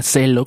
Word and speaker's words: Zello: Esta aplicación que se llama Celo, que se escribe Z-E-L-L-O, está disponible para iOS, Android, Windows Phone y también para la PC de escritorio.